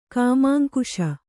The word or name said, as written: ಕಾಮಾಂಕುಶ